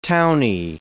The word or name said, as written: townie